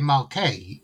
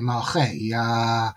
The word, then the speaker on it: Malchei: I think you're referring to the fact that people might say Malkei, but it's actually Malchei.